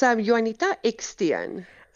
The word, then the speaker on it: Johannita Eksteen: It's Johannita Eksteen.